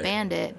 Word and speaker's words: Bandit: More often, people will ask me how to spell it, and I'm like, just like it sounds, right. Bandit, B-A-N-D-I-T.